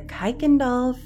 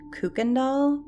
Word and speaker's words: Kuykendall: Number 2, the Kuykendall family phone stalker. You know, every time I see this name, I want to pronounce it Kuykendall, but apparently it is pronounced Kuykendall, even though it's spelled K-U-Y-K-E-N-D-A-L-L.